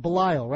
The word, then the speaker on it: Belisle: We're going to call it Belisle, right, Matt? We're going to go with that pronunciation for a while? – I think you nailed it.